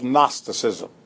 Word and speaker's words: Gnosticism: G-N-O-S-T-I-C-I-S-M. Gnosticism. G-N-O-S-T-I-C-I-S-M. – Knowledge. – But it's pronounced Gnosticism.